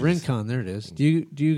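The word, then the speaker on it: Rincón: Rincon.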